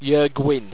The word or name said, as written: yurgwin